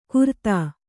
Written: ಕುರ್ತಾ

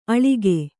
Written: ಅಳಿಗೆ

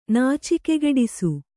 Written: ನಾಚಿಕೆಗೆಡಿಸು

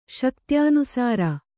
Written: ಶಕ್ತ್ಯನುಸಾರ

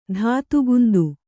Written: ಧಾತುಗುಂದು

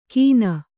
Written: ಕೀನ

